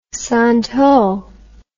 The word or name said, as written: santol